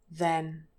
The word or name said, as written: then